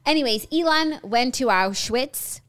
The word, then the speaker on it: Auschwitz: I know this is an important story, but like, how did you just pronounce Auschwitz? – Auschwitz? – You said Auschwitz. – Listen. – Just, not that it matters, but I feel like it's Auschwitz.